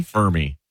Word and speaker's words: Fermi: Fermi. – Fermi. How do you spell that? – F-E-R-M-I.